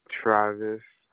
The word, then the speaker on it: Travis: Travis. – Do you spell it T-R-A-V-I-S? – Yes, ma'am.